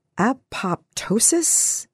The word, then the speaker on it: apoptosis: apoptosis? A-P-O-P-T-O-S-I-S? – Sure.